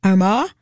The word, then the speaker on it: Armagh: If you want to buy Gosford's Castle in County Armagh in Northern Ireland, Danny, how did you say it? – You said it right. That's the Gaelic pronunciation.